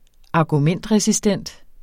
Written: argumentresistent

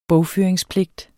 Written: bogføringspligt

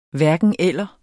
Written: hverken-eller

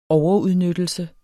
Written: overudnyttelse

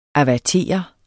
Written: avertere

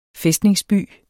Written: fæstningsby

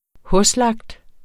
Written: hoslagt

